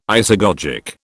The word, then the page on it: isagogic: British English